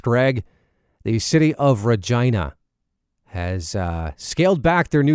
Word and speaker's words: Regina: For example, one of the slogans was Regina, the city that rhymes with fun.